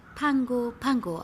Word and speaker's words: Pago Pago: Pago Pago wird Pango Pango ausgesprochen.